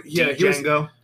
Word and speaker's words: Django: He wasn't Django. He was Django. The D is silent.